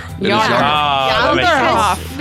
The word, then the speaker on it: Janderhof: I will point out, and I have said Janderhof for the longest time. – Are we pronouncing it wrong? – It is Yonder. – Yonderhof. Because if it's Norse at all, yeah, J-A-N.